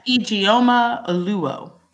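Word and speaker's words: Ijeoma Oluo: Yes, Ijeoma Oluwo.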